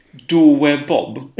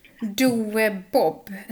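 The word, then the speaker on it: Douwe Bob: Doe Bob. – Doe Bob. Hur stavas det? – D-O-U-W-E. Doe.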